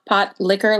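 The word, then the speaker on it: pot likker: It is spelled like pot, P O T, and then liquor, L I K K E R, but it is pronounced okay. like pot, pot liquor, like liquor from a pot.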